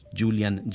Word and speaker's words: Julian: La anterior situación fue detonante para don, en este caso le decimos Julián, pero si lo pronunciamos de la forma como es originaria, es Julian Germain, un joven padre fotógrafo del Reino Unido, que se preguntó, ¿será que las aulas de enseñanza lucen muy distintas alrededor del mundo?